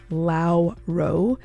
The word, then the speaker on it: Lauro: I want to say their name is pronounced Lauro, L-A-U-R-O.